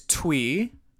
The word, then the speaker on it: Thuy: Okay, my pick is Twee. It's spelled T-H-U-Y.